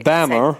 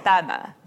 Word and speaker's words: Bamber: And it's spelled B-A-M-B-E-R. But they're English, so they Bammer. – Say Bammer.